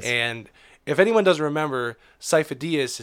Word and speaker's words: Sifo-Dyas: It's Sifo-Dyas. – Sifo-Dyas? – Yes.